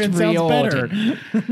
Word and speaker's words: Riordan: It's pronounced Riordan. Riordan.